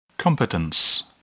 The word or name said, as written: competence